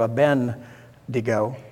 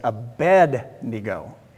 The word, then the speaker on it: Abednego: See, sometimes I'll pronounce Abednego wrong. I'll do Abednego, and it's not that way. It's Abednego. Everybody say Abednego.